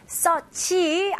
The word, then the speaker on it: search: 오케이, 여기서는 R 발음 힘을 주시고 마지막 CH 발음으로 마무리해 주셔야 됩니다. 그리고 search 아니에요. 네, 이거는 외래어고 영어식 발음은 search. search. 여기 EA가 U라고 상상해서 한번 읽어보세요.